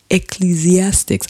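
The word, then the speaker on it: Ecclesiastes: Ecclesiastics. I can only say it when it's slow, when I say it's slow. – Well, you said it right.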